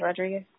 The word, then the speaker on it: Rodriguez: Just the normal spelling, Natalie Rodriguez.